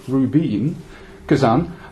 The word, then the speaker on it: Rubin Kazan: Well, Rubin, which translates as Ruby, and is actually pronounced Rubin Kazan, have only recently become a major force in Russian football, being promoted to the top division for the first time in 2003.